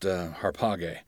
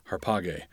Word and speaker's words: harpage: This is a word that also begins in an alpha, but it's pronounced harpage. harpage. And that's because the alpha at the beginning has what in Greek is called a rough breathing mark that makes an H sound.